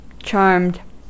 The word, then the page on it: charmed: American English